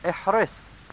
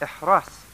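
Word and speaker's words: احرص: He's saying, ihris, which can also be pronounced ihras, with a fatha on the ra also.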